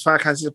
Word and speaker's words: Farrakhan: Louis Farrakhan, was it Farrakhan? – Louis Farrakhan.